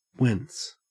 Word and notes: whence: Australian English

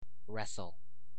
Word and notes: wrestle: American English

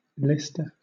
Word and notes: leister: British English

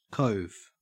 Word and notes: cove: Australian English

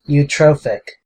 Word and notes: eutrophic: American English

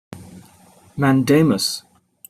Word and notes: mandamus: British English

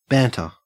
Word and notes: banter: Australian English

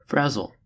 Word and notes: frazzle: American English

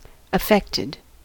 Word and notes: affected: American English

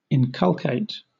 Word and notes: inculcate: British English

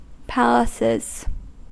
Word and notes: palaces: American English

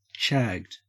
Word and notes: shagged: Australian English

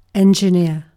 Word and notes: engineer: British English

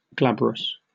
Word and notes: glabrous: British English

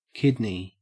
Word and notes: kidney: Australian English

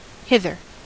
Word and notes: hither: American English

